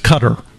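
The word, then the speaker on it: Qatar: This week it's pronounced Cutter.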